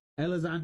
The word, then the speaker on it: Elizondo: E-L-I-Z? – Lewis. Lewis Elizondo. – Okay. – I don't know who that is. – Is that how you say his name? Okay. – He's kind of scary looking. – Elizondo? – Elizondo.